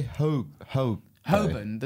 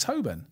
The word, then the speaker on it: Holborn: And you say Holborn? There's no L. – Holborn. It's Holborn. – It's Holborn. – Holborn. – No, it's Holborn. It's a silent L.